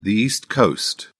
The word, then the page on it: east coast: British English